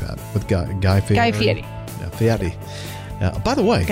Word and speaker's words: Fieri: Guy Fieri.